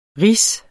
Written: rige

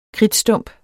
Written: kridtstump

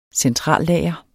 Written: centrallager

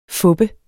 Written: FUPPEDE

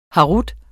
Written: herut

